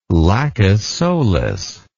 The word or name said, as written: Lacus Solis